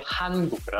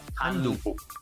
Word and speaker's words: 한국: El país Corea del Sur. En coreano se dice 한국, ¿verdad? – 한국.